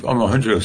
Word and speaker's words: Omohundro: Steve Omohundro said way back in 2008, a paper you can look up called Basic AI Drives. Omohundro is spelled phonetically.